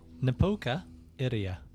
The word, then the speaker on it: Napoka Iria: Napoka Iria. N-A-P-O-K-A-I-R-I-A.